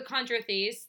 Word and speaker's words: chondrichthyes: But the chondrichthyes, that's how you pronounce it.